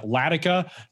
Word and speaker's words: Ledika: You can also follow my personal Twitter account at Ryan Latica. My last name is spelled LEDIKA.